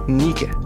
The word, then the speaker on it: Nike: Пишется Ники. Все считают как Nike.